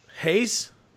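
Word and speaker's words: Reis: Versus Wilson Reese. – Hayes? Yeah, Hayes. It's Portuguese.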